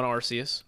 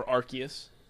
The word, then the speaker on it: Arceus: Ready for Pokemon Arceus or Arceus, however Austin wants. – Either way. It seems like either way, there's no accepted pronunciation.